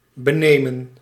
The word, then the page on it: snatch: /snætʃ/